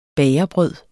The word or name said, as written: bagerbrød